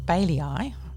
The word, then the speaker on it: BAILII: What I'm about to read in full is from a website called Bailii. I'm choosing to pronounce it that way. B-A-I-L-I-I, which has something to do with publishing law things in the UK.